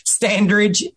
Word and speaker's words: Standridge: It's Jeff Standridge. That's J-E-F-F, and the second F is silent. Little joke for you there. Standridge, and I like to pronounce it as I stand on a ridge, Jeff Standridge, S-T-A-N-D-R-I-D-G-E.